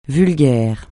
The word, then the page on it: vulgaire: francais